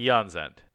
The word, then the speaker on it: Aeon's End: And yes, Eon is the proper way to pronounce it. – Eon's End.